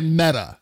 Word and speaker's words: Mehta: Yeah, I was pronouncing it Meta, as in Meta.